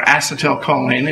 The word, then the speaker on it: acetylcholine: One is the neurotransmitter, acetylcholine, or acetylcholine, if you prefer that pronunciation, and CoA.